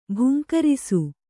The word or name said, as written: ಭುಂಕರಿಸು